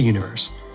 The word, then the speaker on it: Universe: Head to Universe. That's univer.se, but the dot is silent.